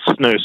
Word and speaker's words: snus: It's called snus. So S-N-U-S.